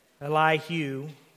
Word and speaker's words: Elihu: So this Elihu, that's how you say it. Elihu. Is it Hugh? Elihu or Hugh? Hugh.